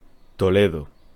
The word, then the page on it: Toledo: Spanish